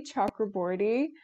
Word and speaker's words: Chakraborty: My name is Promi Chakraborty.